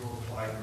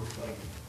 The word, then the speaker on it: Euroclydon: I believe that's the way you pronounce it, Euroclydon or Euroclydon.